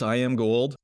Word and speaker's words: Iamgold: I was always kind of wondered, was it Iamgold or Iamgold? It is Iamgold.